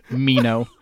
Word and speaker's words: Meno: So his name in Greek is actually Menon, like somewhere between A and E. It's M Eta Nu. Omega Nu, I think is how you spell it in Greek. So it's like it's pretty close. If you want to say Meno, we say it Meno in English.